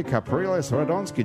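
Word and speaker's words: Capriles Radonski: Marcel is the communications coordinator in New Orleans for the Venezuelan presidential campaign for Enrique Capriles Radonsky.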